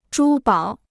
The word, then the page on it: 珠宝: zhū bǎo